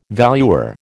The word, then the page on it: valuer: American English